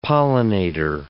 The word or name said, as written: pollinator